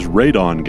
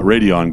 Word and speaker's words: radon: The answer is actually incorrectly pronounced, but it is radon gas, not radion gas.